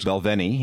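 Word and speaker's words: Balvenie: Belvini, yes. – Belvini, is that how you pronounce it? – Yeah, very good scotch, yes.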